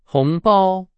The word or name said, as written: 红包